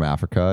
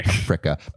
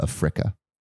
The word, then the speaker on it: Africa: One of my teachers in high school would always pronounce Africa a fricka. – That is strange. – Is that like how it's pronounced? – I do not think so. – A fricka. A fricka. Like that. – No? That is very strange. Okay. I've never heard that pronunciation in my life.